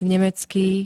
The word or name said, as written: nemecký